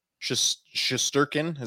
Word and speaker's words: Shesterkin: Shesterkin, is that how you pronounce it? – Yeah.